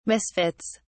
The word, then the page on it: misfits: British English